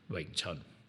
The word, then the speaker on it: Wing Chun: So the V-I-N-G-T-S-U-N spelling is kind of like also now kind of a bit of a generic spelling for the art, but it should always be pronounced Wing Chun.